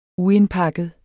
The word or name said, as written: uindpakket